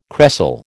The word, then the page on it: cresol: American English